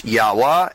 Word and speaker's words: Yahweh: properly pronounced Yahweh, as in the sound of your breath.